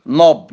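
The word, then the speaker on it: knob: نوب يعني مقبض الباب بنقول عليه اقره الباب عندنا حضراتكم من ايجيبت، كي ان، اي كلمه تبدا بكي ان ما ننطقش الكي.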